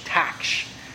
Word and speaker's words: taksh: I'm not sure I can pronounce this right, but it's T-A-K-S-H, taksh, taksh, as best I can understand of how to pronounce that.